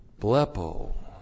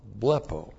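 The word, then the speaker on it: Blepo (βλέπω): The present active imperative of blepo, plus the accusative plural of the reflexive pronoun heautu. Blepo. B-L-E-P, long O. Blepo.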